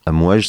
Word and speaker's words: Amwaj: ils font ils ont une chorale qui s'appelle Amouaj, Donc ils ont aussi une, page Facebook. Ça s'écrit A M W A J. Amouaj, ça veut dire les vagues en arabe.